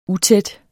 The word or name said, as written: utæt